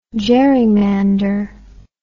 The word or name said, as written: jerrymander